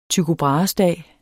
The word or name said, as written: dag